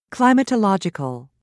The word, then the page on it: climatological: British English